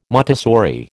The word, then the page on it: Montessori: American English